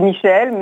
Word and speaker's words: Myshl: C'est Michel, mais ça s'écrit un peu différemment, c'est M-Y-S-H-L, voilà.